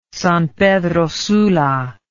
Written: San Pedro Sula

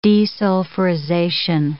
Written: desulfurization

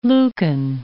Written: Lukan

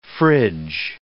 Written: frig

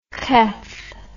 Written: kheth

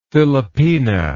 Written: philopena

